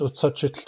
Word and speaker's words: utsachit: Let's break it into syllables. U tsa chit.